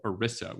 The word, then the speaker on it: ERISA: The other issue has to do with something called ERISA, which rhymes with Melissa because I kept mispronouncing it.